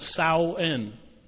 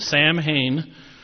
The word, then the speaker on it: Samhain: The holiday was called Samhain, spelled S-A-M-H-A-I-N. It looks like Sam Hain. Every time I see it, I look at Sam Hain, but it's Samhain.